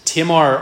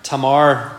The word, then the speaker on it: Tamar: The first woman mentioned is Tamar, or Tamar, depending on your preference for pronunciation, in verse 3.